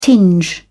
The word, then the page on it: tinge: British English